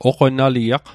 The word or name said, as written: oqonaliaq